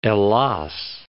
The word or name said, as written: Ellas